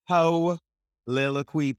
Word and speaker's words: Heauxliloquy: So y'all, Vernon, he hosts a podcast called the Holiloquy podcast, that's spelled H-E-A-U-X-L-I-L-O-Q-U-Y. So he spelled it like we do down here in the South, baby. He threw a little Cajun on there.